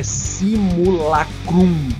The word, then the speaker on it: Simulacrum: É simulacrum, tá? – Que se, se fala, assim, né, como se escreve, né? Com C-R-U-M no final, tá?